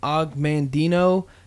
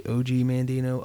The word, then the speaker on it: Og Mandino: This one is from Og Mandino. Depending on how you say his name, some people say OG Mandino, Og Mandino.